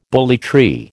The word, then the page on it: bully tree: American English